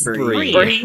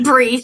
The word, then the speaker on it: Brie: Brie. – Brie.